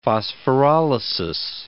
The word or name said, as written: phosphorolysis